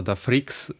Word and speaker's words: Da Freaks: Także czyta się to Da Freaks, z Z na końcu, od motoryzacyjnych freaków.